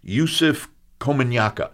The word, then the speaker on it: Yusuf Komunyakaa: There was a poet by the name of, and I hope I'm not destroying this name, Yusuf Komunyakaa. – Yes. – Did I do well? – You did. That's Hurrah. Exactly how you pronounce his name.